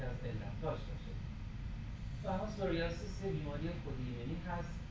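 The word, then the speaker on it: psoriasis: خودش سوریاسیس پیش تلفظ نمیشه مثل نومونیا که پنومونی نیست.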